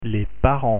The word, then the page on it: parents: French